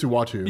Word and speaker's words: Uatu: It's Utah, right? – Well, it wasn't originally. Well, pronunciation. – Yeah, it's Uatu. – Uatu, that's it.